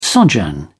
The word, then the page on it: sojourn: British English